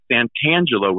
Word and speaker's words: Santangelo: You know, can you tell Vin Scully that it's Santangelo with a T? Can you pronounce the T, please?